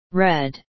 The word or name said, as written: red